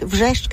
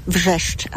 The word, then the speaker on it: Wrzeszcz: Hay un barrio de Gdańsk, Wrzeszcz, que tiene 7 consonantes y una sola vocal, Wrzeszcz.